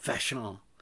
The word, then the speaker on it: Vachon: I say it like the masterminded criminal from the original Hawaii 5-0, Vachon.